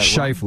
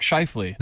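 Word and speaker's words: Scheifele: Mark Schifele, excuse me if I pronounced that Shifley. – Word. Mark Shifley. – There you go.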